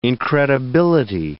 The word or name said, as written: incredibility